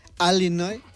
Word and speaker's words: Illinois: Illinois, no lo escucho, doctor. – Sabes que hay, como aquí en España, hay acentos diferentes en Estados Unidos y los nativos del estado de Illinois lo pronuncian Illinois.